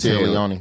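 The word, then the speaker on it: Téa Leoni: was it Tia Leone? Is that how you say it? – Tia Leone.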